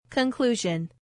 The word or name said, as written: conclusion